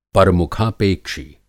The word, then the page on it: परमुखापेक्षी: Hindi